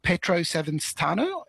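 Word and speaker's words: Pietro Savastano: Petro Savastano is how you say his name.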